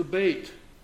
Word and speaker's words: Beit: The second character is the Beit, and that's kind of pronounced that way. It looks like Beth in our English language, but the Beit is the second character, and it specifically gives us a picture of the house.